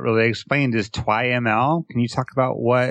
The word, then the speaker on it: TwiML: Yeah, we pronounce it TwiML. So it's Twilio XML.